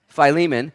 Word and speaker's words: Philemon: starts with Phi, rhymes with Philemon. Philemon, okay, it's a book of the Bible.